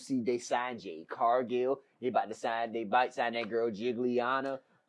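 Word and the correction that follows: Giulia: She's a Japanese broad, and her name is Julia, and it starts with a G.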